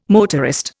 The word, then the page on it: motorist: Indian